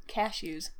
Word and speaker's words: cashews: Technically, no. You're just putting the emphasis on a weird part of the word. – Well, how do you say it? – Cashews.